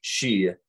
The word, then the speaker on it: sidhe: But in Scots Gaelic, In Scottish, it is not pronounced Sith, it is pronounced she, which is nearly identical to the Irish Gaelic she, which is spelled in English S-I-D-H-E.